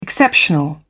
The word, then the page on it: exceptional: British English